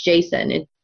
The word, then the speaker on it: Jacin: Well, like we talked about last week, Marissa Meyer says it's Jacin. The way that she says it's pronounced is J-A-Y-S-O-N.